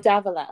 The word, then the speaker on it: Davila: Am I saying your last name wrong? How do you say your last name? Davila? – I say Davila. – Oh, Davila.